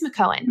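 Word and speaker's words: McKeown: Number two is Essentialism by Greg how do you say his last name? – I believe it's McCohen. – McCohen.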